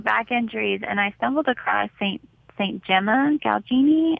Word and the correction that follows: Galgani: I don't know if I'm saying her name right. Yeah. Saint Gemma. I think it's Galgani.